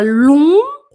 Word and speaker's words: longs: Ela tem, ela, les, les, os cheveux longs cabelos longos que fala lum, mas se escreve GS no final, longs.